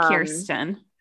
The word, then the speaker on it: Kirsten: I remember my first one was Kirsten or Kirsten, however you want to pronounce it. – We say Kirsten.